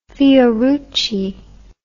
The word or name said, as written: fiorucci